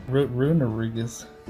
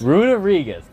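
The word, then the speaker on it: Runerigus: Runarigus. – What'd you say? – Runarigus. – Runarigus.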